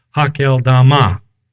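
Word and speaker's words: Hakeldama: And it became known to all who were living in Jerusalem, so that in their own language that field was called," now this is an Aramaic term, you can pronounce it however you like. I am told it is pronounced something like Hakel Dama.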